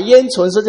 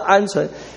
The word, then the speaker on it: 鹌鹑: 神就什么降下他们什么鹌鹑，哎呀，昨天晚上还在准备的时候，才知道那个字啊，不那个它那个字呢，鸟的名字不叫做啊鹰鹑，是叫鹌鹑，这个我们中文的发音，汉字的发音是很不容易的，是非常不容易的。